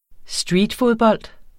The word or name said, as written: streetfodbold